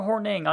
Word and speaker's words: Hornung: I said Hornung because I'm Irish. So I don't know. Is it Hornung or Horning? I know it's pronounced in the States Horning, but is that right?